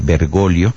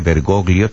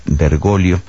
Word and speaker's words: Bergoglio: Esa es la pronunciación italiana del nombre, Bergoglio. No se pronuncia la G cuando va antes de una L en italiano. Hay quienes lo españolizan el nombre, Bergoglio también sería aceptable, aunque la pronunciación en italiano, la pronunciación que corresponde de hecho al apellido es Bergoglio, y por eso pues así le hemos llamado.